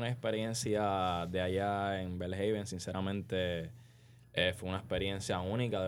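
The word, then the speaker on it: Belhaven: Quiero pronunciarlo bien. Es Bellhaven. – Bellhaven. – Ahí está. Bellhaven.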